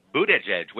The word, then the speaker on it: Buttigieg: Mayor Pete Buttigieg.